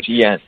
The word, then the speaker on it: Chen: I mean, that's Chen. – Okay, Chen.